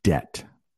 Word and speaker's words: debt: I am saying debt. There is a silent B in that word, debt.